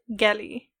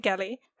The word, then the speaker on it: Geli: okay, my pronunciation of this, I always pronounce her name Geely, but I heard a lot of people pronounce it Geli.